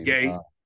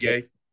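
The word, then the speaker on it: Gueye: Gay. Muhammad Gay.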